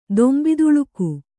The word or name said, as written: ದೊಂಬಿದುಳುಕು